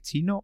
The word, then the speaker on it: Qi: Yo creo que es Chi pero porque Q es una letra, eh, no sé si es porque es chino o porque es una letra griega o algo así, ¿eh? no me digas el por qué, pero se escribe QI, pero es chi.